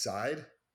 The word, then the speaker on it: Zaid: It's Zaid, Z-A-I-D. I believe that's how you pronounce it.